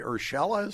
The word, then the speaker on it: Urshela: Now, they traded away Urshela, is that how you say his name? – Yep.